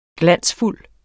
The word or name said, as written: glansfuld